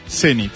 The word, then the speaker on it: Zenit: Son válidas las dos acentuaciones para esta palabra, aunque es preferible la forma aguda, Zenit.